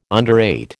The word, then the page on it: underrate: American English